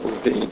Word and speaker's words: Levin: So it was Bernard Levine, but it's spelled Levin, L-E-V-I-N, but he pronounces it Levine, who was head of prevention at MD Anderson Cancer Center.